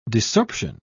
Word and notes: desorption: British English

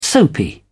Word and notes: soapy: British English

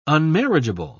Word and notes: unmarriageable: British English

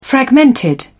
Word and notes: fragmented: British English

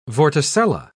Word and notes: vorticella: British English

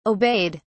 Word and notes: obeyed: British English

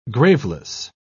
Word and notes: graveless: British English

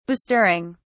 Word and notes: bestirring: British English

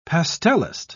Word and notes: pastelist: British English

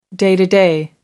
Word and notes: day to day: American English

